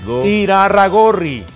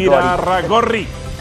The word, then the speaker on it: Irarragorri: Eso dependerá de cuánto quiera gastarse Irarragorri. – Pronúncialo bien. Irarragorri. – Es que me gusta decirle Gorri. – Irarragorri.